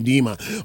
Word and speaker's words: Medeama: Okay, and the pronunciation of the club is Medeama, Mr. President, not Medeama.